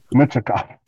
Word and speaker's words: Michkov: Michkov. – Michkov? – Okay. Gotta excuse me. – no problem. Michkov.